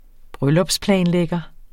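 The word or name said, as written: bryllupsplanlægger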